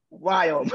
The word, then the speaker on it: Viome: So I always have to like, Viome.